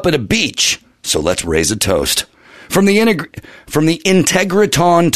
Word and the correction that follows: Integratron: to Joshua Tree. – Close. – Integratron to Joshua Tree.